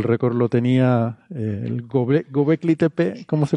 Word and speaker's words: Göbekli: Göbekli le hace un umlaut a la, o, porque tiene diéresis.